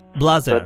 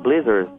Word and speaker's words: Blaser: Well, I come from the French part of Switzerland, so we pronounce Blaser. – Blaser. – But the Swiss German would pronounce Blaser. And English speaker would say Blazer.